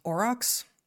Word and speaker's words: aurochs: So the common name is aurochs, I think is how you pronounce that, A-U-R-O-C-H-S.